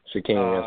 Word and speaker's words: Shaquem: Shaquem, yes, sir.